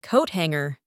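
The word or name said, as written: coat hanger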